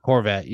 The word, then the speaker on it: Horvat: There is a team in the NHL that's ready and willing to sign a guy like Horvat or how do you say his fucking? I always forget his name. – Horvat. You said it right.